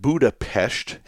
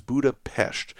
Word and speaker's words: Budapest: I learned a little bit about Hungarian pronunciation, and if you see the letter S all by itself, it is pronounced like SH. If you see the letter S with a Z after it, it is pronounced like a normal S. And since the word Budapest has just a single S in it, that is the correct pronunciation.